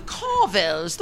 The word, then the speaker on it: Carvel: The Carvels, the Carvels, the Carvels. But in New York, we say Carvel, Carvel. You have to put the accent on the vel, the vel.